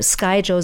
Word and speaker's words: Skyjo: irgendein Liebling sagte, Skyjo sei die richtige Aussprache.